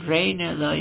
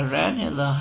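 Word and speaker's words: Ranelagh: یکی از تلفظاش رینلا یا رانلا هست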